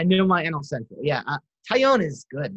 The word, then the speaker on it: Taillon: Can you try to pronounce Jameson Taillon? – Jameson Taillon. – A lot of people butcher that. – Does it sound like Italian or whatever? – A lot of people butcher that. I'm very impressed that you got that.